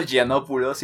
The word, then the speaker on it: Yiannopoulos: Milo Yiannopoulos, y esta es la única vez que vamos a pronunciar bien su nombre.